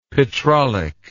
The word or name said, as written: petrolic